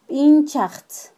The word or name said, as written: tbinchajtz